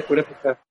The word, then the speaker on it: Purépecha: ¿Cómo se dice eso? Yo lo digo mal. – ¿Cómo se pronuncia? – El rey Purépecha. – El rey Purépecha. Es indígena, ¿no? Es el de indígena de Michoacán, ¿no? – Pero de Michoacán, cultura Purépecha.